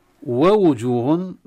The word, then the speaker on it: ووجوه: ووجوه، لا ننسى النطق في الكلمة هذه في ثلاث واوات على فكرة، لا ننسى أن ننطق بالواوين بحركتين كاملتين، واو مفتوحة وواو مضمومة. ووجوه، لأن البعض يسرع فيخطف الحركة خطفا، يقرأ ووجوه يعني ما ينطق، يما الواو الأولى غير مكتملة أم الثانية غير مكتملة.